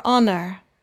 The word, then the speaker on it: honor: H is silent for honor.